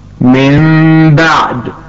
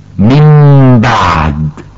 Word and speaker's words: من بعد: من بعد، والصواب أن ننطقها من بعد. – فلقد قلبنا النون الساكنة تماما، لكن أخفينا الميم فلم تعد ظاهرة وواضحة، فصاحبتها أيضا الغنة. واستمعي معي مرة أخرى، من بعد.